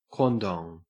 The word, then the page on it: quandong: Australian English